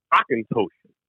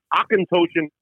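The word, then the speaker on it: Auchentoshan: they call it Ockenpotion. O-C-K. – They pronounce it with the German approach to the A-U-C-H.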